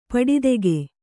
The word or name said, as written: ಪಡಿದೆಗೆ